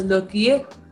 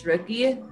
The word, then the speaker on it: Rookie: in Korean, they pronounce it as Looky. But like, if we have to say it in English, it's Rookie.